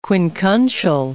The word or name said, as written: quincuncial